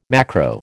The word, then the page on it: macro: American English